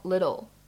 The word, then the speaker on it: little: 而 little 这个单词呢，大家要注意一下，可以做一个美音浊化的处理，就是没有必要在美音当中再把这个 t 的发音呢读得特别的清晰，特别的重。编成 little little。